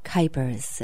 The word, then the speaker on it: Kuijpers: And it was a vegan who was being interviewed, and that was Michael Kuipers.